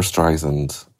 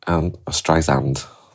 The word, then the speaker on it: Streisand: My question is to do with your love of Barbara Streisand. Streisand, I should say it correctly.